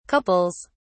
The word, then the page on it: couples: British English